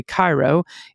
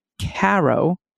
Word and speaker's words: Cairo: For instance, the town of what I would say Cairo is pronounced Caro, like the syrup.